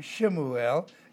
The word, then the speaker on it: Shemuel: And so his name, Shemuel, S-H-E-M-U-E-L, is the way it would be pronounced.